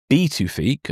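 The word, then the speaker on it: Pituffik: The US already maintains a crucial radar base at Beetufik, apparently that's how you pronounce it. I double checked earlier. Apparently the P is pronounced as a B.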